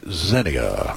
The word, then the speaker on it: zinnia: Down here we say zinnia. – Zinnia. What else? Zinnia. – Zinnia. – Zinnia. It's a two-syllable word.